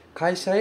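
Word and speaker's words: kaisha (会社): Kaisha e modorimashita. Significa voltei para a empresa. Este kanji se pronuncia kaisha, significa empresa.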